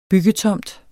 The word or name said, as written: byggetomt